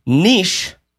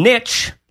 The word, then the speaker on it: niche: So, okay, first of all, got to say, apparently, according to our Canadian listeners, niche as Kelby said it, is the correct way of pronouncing that word. And niche, like as in niche, please, is not at all the right way of doing it, nor is Nietzsche or...